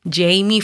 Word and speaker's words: Jeymi: Mi canal de YouTube es mi nombre, Jamie Febles. Recuerda que mi nombre se escribe una J-E-Y-M-I latina.